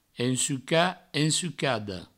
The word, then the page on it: ensucat: ensucada; ensuca; ensucado; provençal